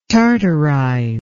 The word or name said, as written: tartarize